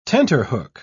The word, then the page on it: tenterhook: British English